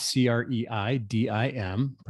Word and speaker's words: Creidim: It's C R E I D I M, pronounced Creidim. You know, it's an it's an Irish term that means believe.